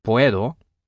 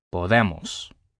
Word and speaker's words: podemos: Remember that when there is no written accent or consonant other than S or N at the end, the stress will always be on the second to last syllable. So it's puedo, but then podemos.